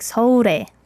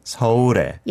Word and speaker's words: 서울에: And then 서울에 should be sound like 서울에. – 서울에. – Yeah, 서울에. – 서울에. Although it is two syllables, 서울, it really does blend together a lot.